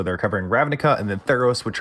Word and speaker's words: Theros: Mythical Odysseys of Theros. Did I pronounce yes. that right? – Theros, correctly.